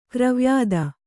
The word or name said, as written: ಕರಿವ್ಯಾಧಿ